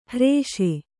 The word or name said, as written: ಹ್ರೇಷೆ